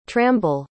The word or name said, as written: tramble